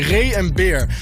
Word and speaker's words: Ray en Beer: Ik wil het alweer op zijn Engels uitspreken. Nee, Ray en Beer.